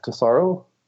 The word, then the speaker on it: Tesauro: That's at Matt, M-A-T-T, underscore T-E-S-A-U-R-O, Matt Tesaro.